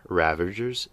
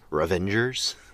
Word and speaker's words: Ravagers: One of my pet peeves is when people mispronounce Ravagers as Revengers, as if these are almost like Avengers, but instead of avenging, they're revenging. These are Ravagers. They ravage. They don't revenge.